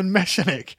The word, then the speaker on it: Meshenick: Evan Meshenick. It's pronounced like mechanic, but with an ash. With an S.